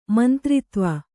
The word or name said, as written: ಮಂತ್ರಿತ್ವ